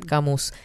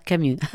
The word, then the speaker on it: Camus: escucho ah, nombrar a Albert Camus sin pronunciar la S. Ah, y yo lo pronuncié, ¿viste? Genia total. Albert Camus.